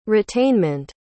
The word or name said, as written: retainment